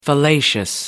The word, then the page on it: fallacious: American English